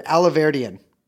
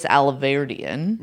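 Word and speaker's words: Alahverdian: Nicholas Alarvidian. – No, I heard a guy pronounce it earlier. Alaverdian. – Okay. Nicholas Alaverdian.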